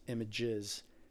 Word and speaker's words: Images: Image without an S. S is silent in French.